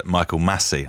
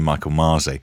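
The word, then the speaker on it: Michael Masi: I was heavily criticised earlier for mispronouncing the name of Formula One's race director, Michael Massey. I called him Michael Marzi, and I knew it was Massey, and I just got it wrong.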